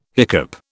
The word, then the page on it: hiccup: British English